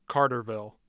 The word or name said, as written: carterville